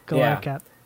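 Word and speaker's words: Galarcep: For those listening and don't know who he is, it's Ives Galarsep, I believe is how you pronounce his last name. – Yeah, Galarsep.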